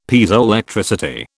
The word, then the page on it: Piezoelectricity: British English